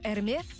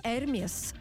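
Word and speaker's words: Hermès: Вместо привычных логотипов написали транскрипцию, то есть вместо «Эрме» нанесли надпись «Эрмес».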